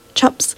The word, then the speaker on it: Chups: And by Lost on Main at 319 Main Street in Chico, presenting the return of Messer Chumps. Chups. I always say that wrong. I know those guys. Messer Chumps.